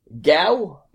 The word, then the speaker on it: Gau: Gao Mansfield, G-A-U Mansfield. I think it's pronounced Gao. I would say Gao.